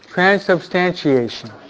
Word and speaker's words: transubstantiation: That is, Can you pronounce that word? Transubstantiation. – Transubstantiation. – Can you say it? – Transubstantiation. – You almost got it. It's a hard word. – Transubstantiation.